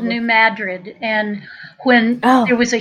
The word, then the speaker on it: Madrid: Madrid, but it's pronounced Madrid.